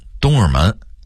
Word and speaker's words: 东直门: 同样东直门的直字也容易被东的音给吞了，直字的发音含糊不清，说成东尔门，大家会说成大家，大家好会说成大家好。